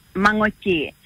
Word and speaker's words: Mangochi: You're pronouncing it. You're really close. It's a hard one. It's called Mangochi, the tail end. The tail end sound is nga nga nga.